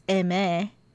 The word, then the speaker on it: Aimer: So I listened to some Aimyon, Aimer, which I think we've been saying her name wrong this whole time, as in French, it's Aimé.